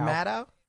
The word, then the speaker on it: Maddow: Okay, so how do you say her name? Because I had somebody today on Twitter basically tell me, you know, I say Rachel Maddow. Is it Maddow? – I say it Maddow.